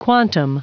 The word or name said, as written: quantum